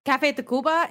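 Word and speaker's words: Café Tacvba: Café Tacuba. It's spelled TACV.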